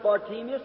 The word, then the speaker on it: Bartimaeus: We know him in the Bible as Bartimaeus. Many pronounce it Bartimaeus. Either way, it'll be all right.